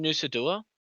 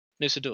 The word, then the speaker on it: Nusa Dua: We stayed in Nusa Dua.